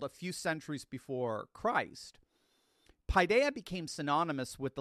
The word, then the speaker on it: paideia: It's spelled P-A-I-D-E-I-A, paideia.